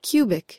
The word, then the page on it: cubic: American English